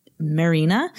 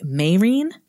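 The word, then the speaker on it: Merina: So what we pronounced as Marina should have been more like Marine.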